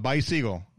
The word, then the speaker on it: Beisiegel: But bicycle. Yeah. Beisiegel. Okay. – Bicycle. Yeah. – Like you're saying bye to a seagull.